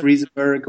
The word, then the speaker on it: Riesenberg: Riesenberg, is it right pronounced, Felix? – I haven't really decided how to pronounce the name in German, but I guess No. – Riesenberg works. – Yeah, Riesenberg, we said in Swedish. – That actually is pretty close.